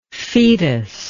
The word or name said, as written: foetus